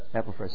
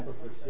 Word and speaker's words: Epaphras: Since we heard, is it, how do you pronounce his name? – Yeah, verse 7. Epaphras. See that? – Epaphras, yeah.